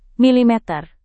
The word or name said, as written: milimeter